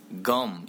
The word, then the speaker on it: gum: 뭐 그리고 껌도 gum 이래야 된대.